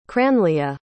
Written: cranleah

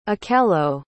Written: akello

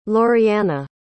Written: lorianna

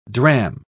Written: drachm

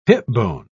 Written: hipbone

